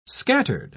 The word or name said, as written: scattered